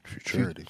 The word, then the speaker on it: Futurity: Futurity? Future, but itty? – Futurity.